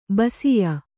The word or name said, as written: ಬಸಿಯ